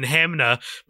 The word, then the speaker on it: Hanmer: My one fun memory of Hamner that I have is there's a saying around these parts where you go, there's no ham in Hamner, because it's spelt H-A-N-M-E-R, not H-A-M-N-E-R, as its oft pronunciation would suggest.